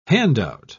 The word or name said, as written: hand-out